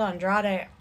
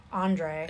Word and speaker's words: Andrade: He called Andrade Andre. – He called him Andre, yeah.